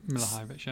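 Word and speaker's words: Milivojević: If you look at Zaha, Townsend, Milivojevic, or however you pronounce his name. – Milivojevic, yeah.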